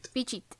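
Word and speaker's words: Phichit: Pichit.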